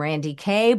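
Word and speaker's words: Randye Kaye: I'm Randy Kay. Both of my names are spelled with a Y-E at the end. The E is silent.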